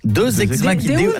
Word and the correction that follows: Deus ex machina: Non, Deus ex machina. – Ah, parce que deux, j'aurais dit que c'était deux ex que t'appelles machine. – Non, ça se prononce Deus ex machina.